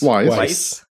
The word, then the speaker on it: Wise: How do you say his last name? Wise? – Wise. – Wise. He's from Indiana, so I didn't think it would go with the full German W, but you never know.